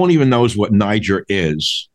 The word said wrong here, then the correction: Niger: Not Niger. Sorry, I'm mispronouncing it. Niger. I must get the proper French pronunciation.